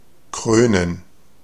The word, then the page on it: couronner: France; [ku.ʁɔ.ne]